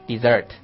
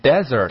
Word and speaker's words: dessert: 嗯，注意啊，甜点这个单词的发音是什么？ – Dessert. – Dessert. – Dessert. – Dessert. – 对，如果读成 desert，那是沙漠。